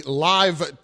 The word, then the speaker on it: LIV: He resigned this morning, and he will get that from the Live Tour. It's spelled L-I-V, but apparently it's pronounced live.